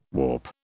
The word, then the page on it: whaup: British English